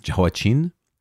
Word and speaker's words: Jehoiachin: I've also heard his name pronounced Jehoiachin with a kind of soft ch, Jehoiachin.